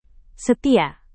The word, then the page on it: setia: Indonesian